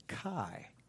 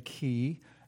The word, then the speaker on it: chi: It comes from the Greek letter chi, which a lot of people call it chi. That's an American anglicized pronunciation. You see two Greek letters up there. You have a letter like this, which is a key, and a capital letter like this.